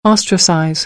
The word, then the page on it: ostracize: American English